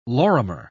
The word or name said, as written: lorimer